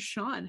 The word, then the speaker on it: Sean: I am joined today by Desmond Osiris, otherwise known as Sean. How are you doing today, Sean? – I'm doing well, thank you. – Am I pronouncing that correctly? – That's correct, you got it.